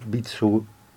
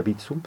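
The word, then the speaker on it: Vitsœ: Vitsu, es difícil de pronunciar, se escribe U-V-I-T-S-O-E, parece que se pronuncia Vitsu,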